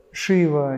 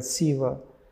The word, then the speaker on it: Шива: Некоторые говорят Шива, некоторые говорят Сива. И так, и так правильно, если в итоге речь идет о сердце.